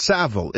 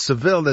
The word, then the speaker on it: Savile: There's a proper way because the way that it's spelled, there's one L, so it's Savile. If it's Seville, as you had suggested incorrectly, we might add it's Seville.